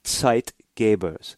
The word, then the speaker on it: Zeitgeber: It comes from the German. It literally means time giver. If you were to write it out, you'd spell it Z-E-I-T-G-E-B-E-R, Zeitgeber. Well, what does that mean?